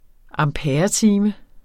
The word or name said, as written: amperetime